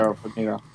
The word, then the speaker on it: Farmiga: Yeah, Vera Farmiga.